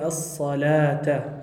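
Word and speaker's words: الصلوة: Das Wow spricht man hier nicht aus. Man sagt nur Es-Salata.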